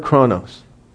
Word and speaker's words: chronos: It is the word C-H-R-O-N-O-S. C-H-R-O-N-O-S.